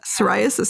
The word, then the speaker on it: psoriasis: And, you know, whether there's an X, eczema, psoriasis, silent P, I did not know any of this.